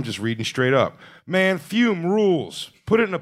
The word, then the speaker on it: Fum: Of course, that is Fume. Pronounced F-U-M-E, but spelled F-U-M.